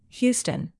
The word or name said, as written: houston